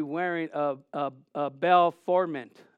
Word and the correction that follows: Baphomet: Maybe I didn't say it right. Did I say it right? Baphomet.